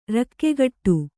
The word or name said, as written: ರಕ್ಕೆಗಟ್ಟು